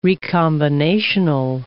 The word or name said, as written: recombinational